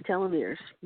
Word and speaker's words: telomeres: telomeres? Is that how you pronounce it? – Yes, telomeres.